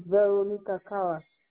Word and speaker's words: Veronica Kawa: Veronica Kawa. – Can you spell that? – V E R O N I C A. And then Kawa, K as in king, A as in apple, W as in woman, and A as in apple again.